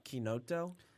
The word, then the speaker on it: chinotto: So in the U.S., I think you can get away with like chinotto. – Okay. – Over there, they pronounce it like chinotto. – I'll have to make sure that my Italian ancestors know that I'm botching it.